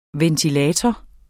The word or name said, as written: VENTILATORER